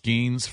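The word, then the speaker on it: Skenes: I think his last name is pronounced Skeens from LSU, who the Nats may take at number two.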